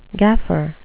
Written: gaffer

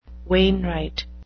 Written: wainwright

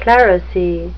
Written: clerisy

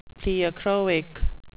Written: pleochroic

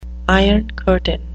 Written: iron curtain

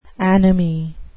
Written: anomie

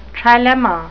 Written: trilemma

